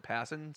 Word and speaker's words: Passan: I've never really known how to say his name, but I've always heard him pronounce it Passan.